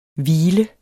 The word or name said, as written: hvile